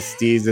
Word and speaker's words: Steeze: The Stees is spelled STEEZE.